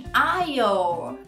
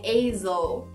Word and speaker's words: aisle: Esse é bem confuso porque ele começa com A, dá vontade de falar A, e tem o S que é mudo, mas se diz aisle. Você não faz nem o som A e nem o S. Não é aisle ou aisle, é aisle.